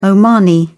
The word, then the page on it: omani: British English